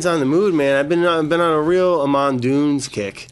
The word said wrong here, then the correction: Amen Dunes: No, Dunes. Maybe it's Amen. Amen Dunes. But A-M-E-N Dunes.